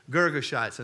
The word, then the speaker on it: Girgashites: The Hittites, the Girgashites. I had to listen. I went to a Bible pronounced thing, and I had to listen to it about 20 times before it ever got in me. Girgashites, and the Amorites, and the Canaanites, and the Perizzites, and the Hittites, and the Jebusites.